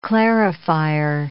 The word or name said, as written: clarifier